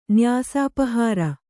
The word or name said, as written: ನ್ಯಾಸಾಪಹಾರ